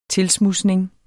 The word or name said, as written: tilsmudsning